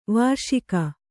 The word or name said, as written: ವಾರ್ಷಿಕ